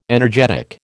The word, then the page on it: energetic: American English